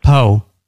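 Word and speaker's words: Po: Spell it for me. – I thought I already did. P-O.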